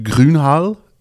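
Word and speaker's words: Gruene Hall: Dus het is eigenlijk moet je het uitspreken als Groenhall.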